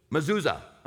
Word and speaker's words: Mezuzah: Mezuzah.